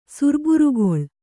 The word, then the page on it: ಸುರ್ಬುರುಗೊಳ್: kannada